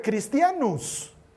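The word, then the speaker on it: cristianus (Χριστιανός): De hecho, utilizaron un término en griego que eh, se pronuncia cristianus.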